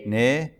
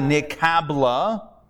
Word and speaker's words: nikabila: Nika-bila. – Nika-bila. Nika-bila. As opposed to nikabla, but if you go to synagogue and you hear people saying it, they might say nikabla instead of nikabila.